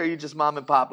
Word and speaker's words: mömandpöp: And if you were to pronounce it the way it would be with the umlauts, it would be mömandpöp.